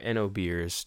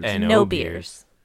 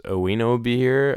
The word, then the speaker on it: oenobeer: You know, when I first saw this word, I thought it was pronounced Oweno beer or Oweno beer. Oweno beer. – Oweno beer. – But it's spelled O-E-N-O-B-E-E-R.